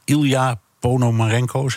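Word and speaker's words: Illia Ponomarenko: Europa-verslaggever Geert-Jan Haan, jij stelde die vraag aan Ilja Ponomarenko, zeg ik het goed? – Jazeker.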